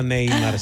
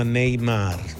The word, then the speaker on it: Neymar: Non si chiama Neymar, si chiama Neymar.